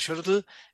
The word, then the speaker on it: Xolotl: Estamos hablando, ella es coordinadora de la campaña Adopta Xolotl, ahora sí lo dije bien, si es así, Adopta Xolotl